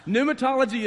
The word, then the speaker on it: pneumatology: Pneumatology is the doctrine of the Holy Spirit. That's what pneumatology is. So, pneumatology. – N-U-M-A-T-O-L-G-Y. – That's very good, but it's also wrong. P-N-E-U-M-A-T-O-L-O-G-Y. It's the silent P.